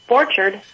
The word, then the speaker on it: Borchardt: You're really close. Borchardt.